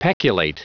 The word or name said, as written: peculate